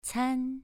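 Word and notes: can: tone 2

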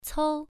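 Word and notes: cou: tone 1

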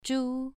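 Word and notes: zhu: tone 2

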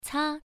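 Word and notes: ca: tone 1

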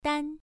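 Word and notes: dan: tone 1

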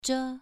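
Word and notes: zhe: tone 2

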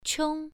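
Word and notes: qiong: tone 1

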